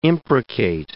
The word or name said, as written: imprecate